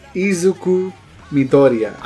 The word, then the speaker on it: Izuku Midoriya: Izuki, yeah. – Izuku Midoriya.